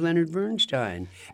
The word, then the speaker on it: Bernstein: My impression, I thought he was Leonard Bernstein. And by the way, we're saying it right.